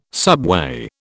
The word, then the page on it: subway: British English